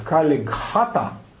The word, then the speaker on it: Калигхата: Калигхата так произносили, а англичане записали как Калькхата, и мы это знаем как Калькута.